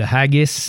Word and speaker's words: haggis: Le haggis. – Oui, le haggis. – Peux-tu me l'appeler? – H-A-G-G-I-S. Je pense que je le prononce comme du monde, le haggis. – Oui, haggis.